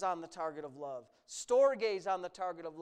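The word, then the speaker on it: Storge (στοργή): There's the Storge, it's how it's pronounced, love.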